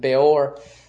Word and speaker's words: Beor: I'm not sure how people pronounce it, but that's how I pronounce it, Beor, B-E-O-R.